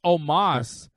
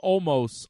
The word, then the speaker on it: Omos: You know, this guy, half your roster can't pronounce his name. It's Omos. Everybody calls him Almost.